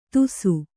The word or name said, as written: ತುಸು